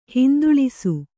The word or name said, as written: ಹಿಂದುಳಿಸು